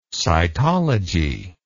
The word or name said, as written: sitology